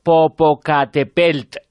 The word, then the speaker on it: Popocatépetl: También nos escribe, supongo que desde México, Guillermo Pérez Aguán, nos dice, decidlo bien, volcán Popocatépetl. O sea que, pues eso, el popo. – Popocate, con tilde en la primera E, o sea, en la cuarta sílaba.